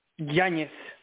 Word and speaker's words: Yañez: Yañez. My name is Yañez.